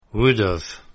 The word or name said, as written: would've